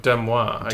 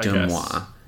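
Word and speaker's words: DeuxMoi: Oh, Demois. – Demois.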